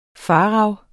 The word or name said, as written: farao